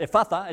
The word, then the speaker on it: Ephphatha: So there's a deep sigh, and then he pronounces this word, Ephphatha, which sounds a bit like Mufasa, but it's not. It's Ephphatha.